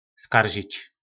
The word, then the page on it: Skaržič: Po našimu